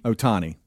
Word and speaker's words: Ohtani: Shohei Ohtani? – Ohtani. – Ohtani. I'm going to put an O there then.